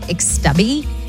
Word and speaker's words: YXSTABY: According to stuff online, it's pronounced Xtubby.